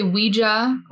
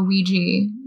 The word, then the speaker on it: Ouija: And you can say Ouija or Ouija, both are fine.